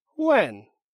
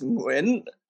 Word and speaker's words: Nguyen: It's pronounced Nguyen, and the beginning of that is kind of like the end of ing, just carried on. It's just a weird way to pronounce those letters, and if your tongue touches the roof of your mouth, you're probably pronouncing it incorrectly.